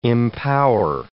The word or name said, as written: empower